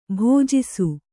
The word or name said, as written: ಭುಜಿಸು